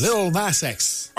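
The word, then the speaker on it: Lil Nas X: Little Nas X? – Little Nas X.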